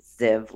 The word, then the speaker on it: Ziev: And it's my name, which is spelled S-T-E-F like Frank, Z-I-E-V, which is pronounced Ziv like live.